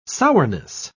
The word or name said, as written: sourness